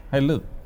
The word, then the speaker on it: Hallelujah: La CH no se pronuncia en el hebreo. Así que es Hallelujah.